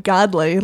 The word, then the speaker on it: Godley: Godley, like the man upstairs.